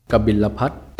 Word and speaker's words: กบิลพัสดุ์: สวัสดีครับวันนี้พบกับรายการคำวัดสำหรับวันนี้เสนอคำว่ากบิลพัท